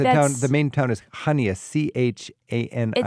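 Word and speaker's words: Chania: I think, is how you pronounce it, Kania? Is that where I should be looking at? – I don't know. – Hania, you mean Hania? – Yeah. – Hania.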